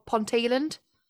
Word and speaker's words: Ponteland: Ponteland, does that sound a bit weird to you? Ponteland. And if you don't know what how to pronounce it, some people down south are like, Ponteland, Ponteland. And I'm like, no, it's Ponteland.